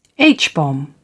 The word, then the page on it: h-bomb: British English